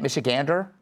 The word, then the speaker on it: Michigander: Kat, as a native Michigander, is that how you say it? Michigander? – Michigander, yeah.